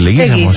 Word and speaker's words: Leguizamo: ¿Cómo se pronuncia Leguizamo. Finalmente? Leguizamo, ¿sí o no? – Sí.